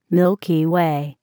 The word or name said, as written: Milky Way